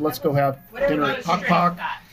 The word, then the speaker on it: Pok Pok: It's called, I'm probably butchering it, Pok Pok? – That's right.